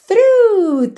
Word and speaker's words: through: Duas palavras aqui que algumas pessoas podem achar que elas são difíceis de pronunciar, talvez pela escrita, mas na verdade não são. Whole, whole, tá? W-H-O-L-E whole, whole. E through, T-H-R-O-U-G-H, through, through. Quite simple, right?